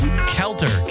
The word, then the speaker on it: Kelter: Kelter, K-E-L-T-E-R. – K-E-L-T-E-R? – T-E-R. – It's pronounced Kelter. – Correct.